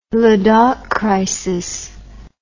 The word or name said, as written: Ladakh crisis